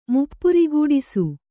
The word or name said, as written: ಮುಪ್ಪುರಿಗೂಡಿಸು